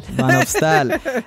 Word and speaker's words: Van Opstal: I am here with Sandra Maria Van Opstel. How do you pronounce the last part of that? – Van Opstal. – Van Opstal.